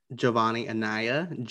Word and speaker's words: Jovonnie Anaya: If you want to follow me on Twitter, it's at Jovonnie Anaya, JOVONNIE.